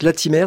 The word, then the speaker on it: Latimer: Léo Latimer, on prononce Latimer ? – Oui, on prononce Latimer.